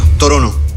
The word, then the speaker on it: Toronto: Toronto. – Torono. I canadesi non pronunciano la T.